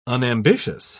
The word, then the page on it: unambitious: British English